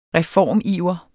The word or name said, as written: reformiver